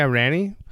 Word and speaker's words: Rannie: R-A-N-N-I-E. – Ranny? – Yeah, Ranny.